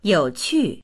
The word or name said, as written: you3qu4